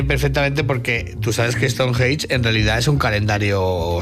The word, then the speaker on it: Stonehenge: Se dice Stonehenge.